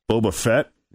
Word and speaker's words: Boba Fett: Plus sexy stormtroopers and an erotic Boba Fett. Is that how you pronounce his name? – Yep.